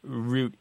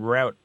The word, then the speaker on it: route: Yeah, but there's really no one preferred pronunciation over the other. – No, there isn't. Not in the United States. Mm. Now, I understand in the UK... root is the standard pronunciation, and route only generally exists in military circles.